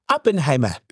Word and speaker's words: Oppenheimer: You have to say it correctly. Oppenheimer.